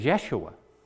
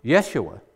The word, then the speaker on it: Yeshua: Now, Jesus is the Greek form of Joshua, which also could be pronounced as Yeshua. Yeshua.